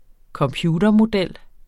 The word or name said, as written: computermodel